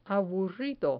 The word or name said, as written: aburrido